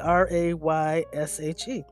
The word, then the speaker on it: reishi: The correct pronunciation, I'm told, is reishi, R-A-Y-S-H-E, just phonetically. But reishi is spelled R-E-I-S-H-I, pronounced R-A-Y-S-H-E, is also called varnished conch, phantom mushroom, and 10,000 year mushroom.